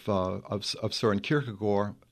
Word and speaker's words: Søren Kierkegaard: I am a scholar of Søren Kierkegaard, as it's pronounced in Danish, and I suppose he would be one of my favorites.